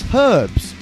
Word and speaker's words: herbs: But it's herbs, not herbs. You spell it H-E-R-B-S, but you call it E-R-B-S.